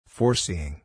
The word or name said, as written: foreseeing